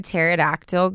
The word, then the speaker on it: pterodactyl: Why can't you hear a pterodactyl go to the bathroom? – You know it? – No. – Because the P is silent.